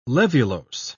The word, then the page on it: laevulose: British English